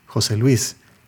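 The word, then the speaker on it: José Luis: In Spanish, any name that starts with Maria or Jose becomes a compound first name that is pronounced as a single word, emphasizing the accent on the second name.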